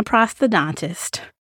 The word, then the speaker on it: prosthodontist: It is my prosthodontist.